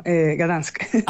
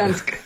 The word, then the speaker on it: Gdańsk: y luego digo Danzig, y es como, ah, no, no, Gdansk. – Ah, claro. – Dansk, Dansk, lo que dicen.